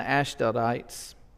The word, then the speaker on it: Ashdodites: But when Sanballat and Tobiah and the Arabs and the Ammonites and the Ashdodites,